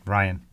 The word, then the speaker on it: Rian: Ryan.